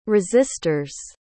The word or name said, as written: resistors